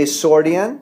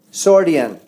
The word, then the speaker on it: Sordean: Sordian. – Sordian. – Like accordion. – Sawyer? – Sordian. Like accordion. S-O-R-D-E-A-N.